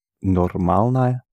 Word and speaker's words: нормальная: Нормальная. – Yeah, don't pronounce no or because it sounds stressed. So it's нормальная.